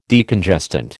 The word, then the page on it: Decongestant: American English